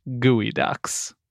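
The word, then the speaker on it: geoduck: I know you're reading this and it says Geoducks, right? – Yeah. – It's pronounced Gooey Ducks.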